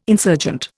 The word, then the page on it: insurgent: Indian